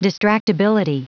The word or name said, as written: distractibility